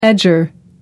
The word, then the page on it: edger: American English